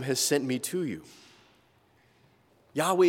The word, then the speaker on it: Yahweh: In Hebrew, those four letters, YHWH, it's pronounced Yahweh.